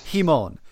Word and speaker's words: Hemon: I should point out before we go further that that is how we're pronouncing that guy's name. Hemon, like how Bono pronounces lemon in that one U2 song, because otherwise we're going to be snickering our way through this episode like two 13-year-old boys watching Justice League versus Teen Titans.